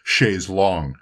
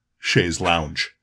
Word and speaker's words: chaise longue: I do really have a great indebtedness to Wet Leg for making sure that I understood that the proper pronunciation is chaise longue and not chaise lounge.